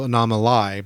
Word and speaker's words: Anomalie: It's spelled A-N-O-M-A-L-I-E, so Anomaly, basically.